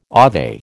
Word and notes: ave: American English

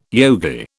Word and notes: yogi: British English

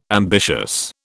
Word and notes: ambitious: British English

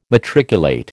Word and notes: matriculate: American English

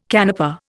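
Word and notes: canapé: Indian

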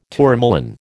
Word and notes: tourmaline: American English

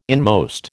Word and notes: inmost: American English

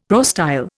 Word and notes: prostyle: Indian

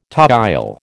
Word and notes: toile: American English

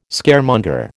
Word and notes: Scaremonger: American English

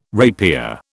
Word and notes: rapier: British English

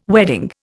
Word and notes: wedding: Indian

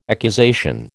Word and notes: accusation: American English

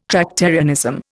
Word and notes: Tractarianism: Indian

